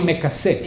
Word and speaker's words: me casé: You say me casé. – Me casé. – Me casé o me casé. This Z sound is characteristic of Judeo-Spanish. And it's a very old pronunciation of the S.